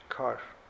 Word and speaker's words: Carr: Carr. What is history? C-A-R değil mi? – C-A-R-R. – Carr. Carr diye okunuyor. Carr diye. – Carr. C-A-R-R. – Double R. Evet, R uzatılıyor.